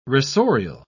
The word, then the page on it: rasorial: British English